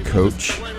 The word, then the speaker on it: Koch: it's called Robot Coach, I think is the correct pronunciation. It's Robot C-O or K-O-C-H.